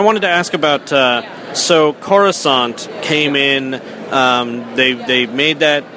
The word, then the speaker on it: Coruscant: Well, I did the dictionary pronunciation, which is Coruscant, which means glittering.